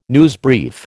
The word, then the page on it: newsbrief: American English